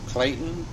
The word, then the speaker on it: Clayton: Clayton Stevens, C-L-A-Y-T-O-N. S-T-E-V-E-N-S.